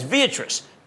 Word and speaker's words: Viatris: Next up, roughly 2 years ago, Pfizer spun off its off-patent and generic drug business as Viatris. It rhymes with Beatrice.